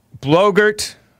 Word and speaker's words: Blogurt: And Blogurt, which rhymes with yogurt.